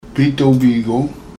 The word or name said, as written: Biitoobiigong